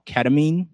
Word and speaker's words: ketamine: And we were discussing that we found out that Matthew Perry was on a drug called ketamine. Am I saying that correctly? – I believe so. Yes.